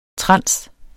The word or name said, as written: trans